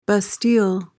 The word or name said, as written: Bastille